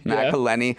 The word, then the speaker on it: McElhenney: But he and It's Always Sunny in Philadelphia star Rob McElhenney, did I pronounce that right? – McElhenney, yeah.